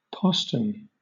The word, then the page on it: postern: British English